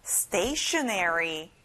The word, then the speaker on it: stationery: 학용품 stationery. 앞에 stay에 강하게 말해보세요. Stationery.